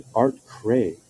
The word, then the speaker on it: Aart Kraay: economists David Dollar and Art Cray have argued, and that's so cool, his name is spelled A-A-R-T-K-R-A-A-Y. So U.S.